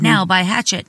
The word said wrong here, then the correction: Hachette: I said it right, didn't I? – Hachette. – Oh, Hachette. Whatever. – Hachette. – Whatever. – It's a Hachette.